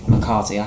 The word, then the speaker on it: McCarty: but Ted McCartney as... – McCarty, I can never say his name.